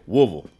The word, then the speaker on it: Woodville: And that includes taking on new challenges, like trying his hand at high-speed game hunting in Woodville, Mississippi. – Or like the locals say, Woolville. Woolville? – I'll let Isaac take it from here.